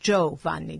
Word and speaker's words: Giovanni: But the I just makes the G soft. If the I wasn't there, it would be Giovanni. So the I makes it Giovanni, Giovanni.